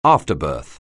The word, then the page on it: afterbirth: anglais